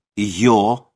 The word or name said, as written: ё